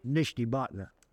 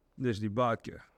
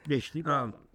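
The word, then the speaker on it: Nishnabotna: Nishnibotna. – Now say that again, Commissioner. – Nishnibotna. – Nishnibotka. You said it, not me. – Nishnibotna.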